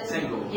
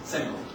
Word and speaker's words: Senghor: Senghor. – Yeah. – Yes, Senghor.